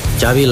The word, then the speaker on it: Xavi: Yeah, Xavi like chocolate.